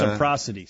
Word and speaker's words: reciprocity: It's reciprocity. Sorry. – Reciprocity. – I was adding an extra syllable there.